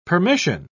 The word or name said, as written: permission